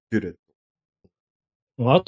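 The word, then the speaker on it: bullet: その発音っていう意味では、まあ、ビュレット。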